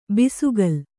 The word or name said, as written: ಬೆಸಕೋಲು